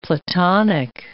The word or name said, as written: Platonic